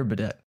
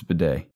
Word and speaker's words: bidet: What's y'all's opinion on bidets? Have you ever tried one?" – Bidets. – I've not tried one. – Is it bidet or bidet? – It's bidet. – Okay. I thought it was bidet.